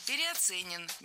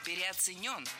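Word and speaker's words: переоценён: Потому что труд медиков во время Великой Отечественной войны, он, конечно, переоценен быть не может. – Переоценен. Неверно. Ударение в этом слове следует ставить на последний слог, в котором должна быть наша любимая «ё». Переоценен.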